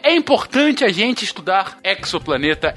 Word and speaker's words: exoplaneta: E, e assim, pelo português correto, seria exoplaneta, porque a gente fala exógeno, a gente fala exoesqueleto, a gente fala exoderme, exoplaneta.